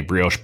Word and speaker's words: brioche: The Hilltopper, Angus patty topped with bacon and crumbled blue cheese on a brioche bun.